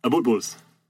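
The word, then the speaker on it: Abutbul: Am I saying that right? – Abutbuls.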